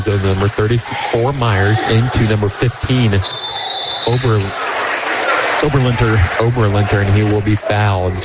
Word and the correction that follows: Oberlittner: Oh, I said that wrong. It's Oberlittner.